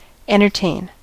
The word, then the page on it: entertain: [ˌɛn.tɜː.ˈteɪn]; /ˌɛntəˈteɪn/; /ˌɛntɚˈteɪn/